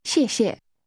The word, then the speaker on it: 谢谢: 谢谢. 谢. 谢谢。谢谢。 – That's pronounced with two falling tones.